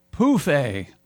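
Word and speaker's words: pouffe: Anyway, Europeans of a certain age use the French word pronounced pouffet for an ottoman, phonetically spelled POOF-A.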